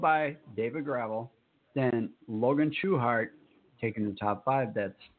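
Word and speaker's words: Schuchart: I looked up the correct pronunciation, even though I can't pronounce correct right now. Logan Schuchert, or Schuhart, or whatever I was trying to pronounce it as. It's pronounced Schuhart.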